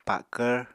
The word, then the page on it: pakvr: Apatani